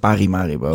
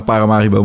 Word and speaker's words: Paramaribo: Maar toen zei ik Parimaribo. – Oh, ja. Nee. – Dus die heb ik heel veel van Surinaamse mensen om mijn oren gehad. Van, yes, leer eerst even die stad goed uitspreken.